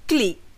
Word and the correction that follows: colleague: which can sound quite odd for non-Malaysian English speakers. This is my clique, ama, for example. The correct pronunciation of the word has two syllables, not one, and the stress falls on the first syllable. Colleague. Colleague.